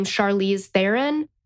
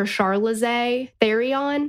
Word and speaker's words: Charlize Theron: And I mentioned because, well, Torella's husband did not know how to pronounce the name Charlize Theron and called her Charlize Therion.